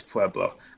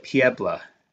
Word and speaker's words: Pueblo: It is Pueblo. I thought you people, you know, so I said you people, pronounced it Piebla. Some weird, because Coloradans have this weird way of pronouncing a lot of their cities. – I could be wrong, but I've always referred to it as Pueblo.